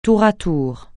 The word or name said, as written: tour à tour